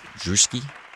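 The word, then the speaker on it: Rzewski: That was a performance of Coming Together by Frederick Drewski. That's how you say his name. It's Polish. Had a whole bit last time.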